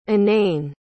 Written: anane

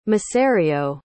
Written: macerio

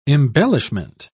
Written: embellishment

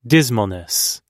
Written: dismalness

